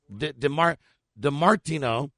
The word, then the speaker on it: DiMartino: Sincerely, Vinny Dimatriano. DiMartino. – Sounded like Dimatriano. Sounded good. – Vinny DiMart. – How do you spell it? – D-I-M. – Uh-huh. – A-T. – Uh-huh. – I-N-O. – DiMartino.